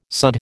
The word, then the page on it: sudd: American English